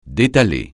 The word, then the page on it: détaler: francais